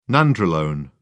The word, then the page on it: nandrolone: British English